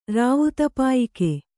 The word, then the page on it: ರಾವುತಪಾಯಿಕೆ: Kannada